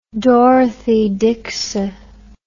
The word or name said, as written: dorothy dixer